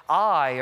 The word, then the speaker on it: Ai: Ai or Ai. It's pronounced both ways. You can come to me after service and argue and say, I think it's Ai. I think it's Ai. I can find 10 scholars on both sides that'll tell you that it's pronounced one way or the other.